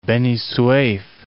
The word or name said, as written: Beni Suef